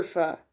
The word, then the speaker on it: pho: Pho is a Vietnamese soup for anyone who's kind of lost. It's really, really good. And it's not pho. It's spelled pho. It's not pho. It's pho. Get it right.